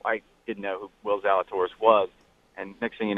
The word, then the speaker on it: Zalatoris: Real quick, I wanted to get his take on, and I think I always pronounce his name wrong, but Will Zalatoris, or is it Zalatoris? How do you pronounce that? – In fairness, it's Zalatoris, but